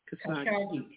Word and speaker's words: Khashoggi: Khashoggi. K-A-S-H-O-G-G-I.